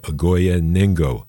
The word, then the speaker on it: Ogoya Nengo: And her name is spelled O-G-O-Y-A, Ogoya, and then N-E-N-G-O, Ogoya Nengo.